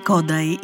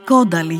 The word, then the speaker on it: Κόνταϊ: Ο Ζόλταν Κόνταϊ ή Κόνταλι για όσους δεν ασπάζονται την ουγγρική προφορά